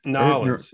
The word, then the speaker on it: New Orleans: Nolens. It's pronounced Nolens.